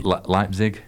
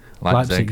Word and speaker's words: Leipzig: How do you say Leipzig? – Leipzig, yeah.